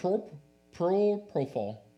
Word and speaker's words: propofol: Oh, propofol.